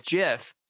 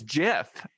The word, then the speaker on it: GIF: It is JIF. – No, the G is short for graphic, which is not pronounced graphic. – You should look it up on the Google. It is like the peanut butter.